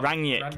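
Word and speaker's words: Rangnick: Uh, so Ralph Ragnick, Rag- Ragnick there you go. come on. Ranick. – Yeah, I mean, I've been saying it right all the time, and then i uh, messed it up.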